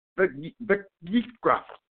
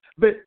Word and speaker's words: Vayikra: Look here, Katria. I understand. Vayikra. Okay? Vayikra. – It's three syllables.